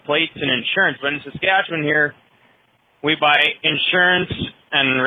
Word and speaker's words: Saskatchewan: I can't do it how he did it, but I don't think we pronounce it quite like he did. We kind of just blur it as Saskatchewan. – Saskatchewan.